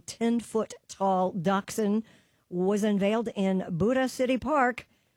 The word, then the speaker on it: Buda: Oh, no, he says Buddha. – Are you sure this isn't Buddha? The Buddha economic? – No, it's pronounced like beauty, so it's Buddha. B-U-D-A. It's so in Buda.